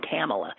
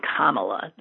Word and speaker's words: Kamala: And by the way, I've been mispronouncing her name. I've been saying Kamala, and I can tell from the people who definitely know her saying Kamala that that must be it, Kamala Harris.